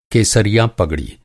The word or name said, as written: पगड़ी